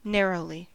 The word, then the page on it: narrowly: American English